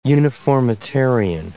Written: uniformitarian

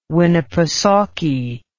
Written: winnipesaukee

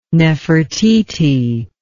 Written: nefertiti

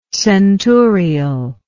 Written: centurial

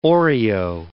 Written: Oreo

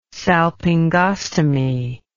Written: salpingostomy

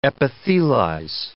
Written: epithelize

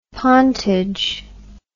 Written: pontage

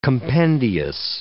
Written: compendious

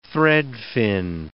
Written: threadfin

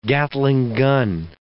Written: Gatling gun

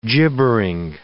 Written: gibbering

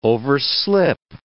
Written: overslip

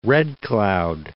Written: Red Cloud

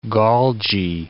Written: Golgi

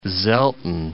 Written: Selten

